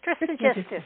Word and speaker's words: Trismegistus: Did I say it right? – Trismegistus, yes.